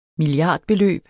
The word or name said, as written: milliardbeløb